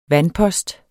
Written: vandpost

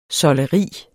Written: SOLDERIET

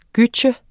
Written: gytje

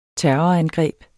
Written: terrorangreb

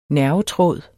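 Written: NERVETRÅD

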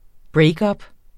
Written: breakup

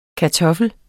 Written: kartoffel

